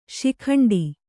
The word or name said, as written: ಶಿಖಂಡಿ